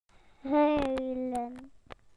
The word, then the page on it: to cry: British English